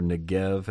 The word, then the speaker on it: Negev: Whenever you see Negev, or Negev as it would be pronounced,